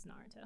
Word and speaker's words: Naruto: I always say it wrong. I'll be like Naruto, but whatever. – Well, I guess that's the way you just read it, but it's Naruto. Naruto.